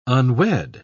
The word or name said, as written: unwed